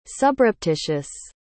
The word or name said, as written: subreptitious